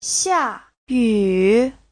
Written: xia4yu3

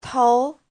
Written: tou2

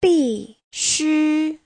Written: bi4xu1

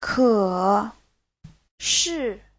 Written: ke3shi4

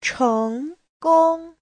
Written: cheng2gong1

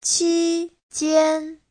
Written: qi1jian1

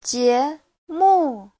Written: jie2mu4

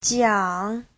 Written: jiang3

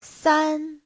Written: san1

